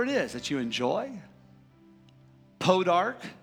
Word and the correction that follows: Poldark: I didn't say it right, did I? What's it called, Amy? Poldark.